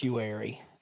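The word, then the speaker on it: Uwharrie: That's how they pronounce it down there. – It's Uari. If you don't think so, you're wrong.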